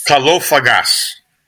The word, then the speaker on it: Kalofagas: But before we jump in, tell us about, and I hope I said it right, Kalafagos? – Kalofagos. – Kalofagos. – The accent on certain syllables is different. So it's kalo, which is good. Fagas is eater. And so it's a compound word in Greek, kalofagos.